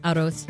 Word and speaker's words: arroz: Arroz. – That's rice. Arroz.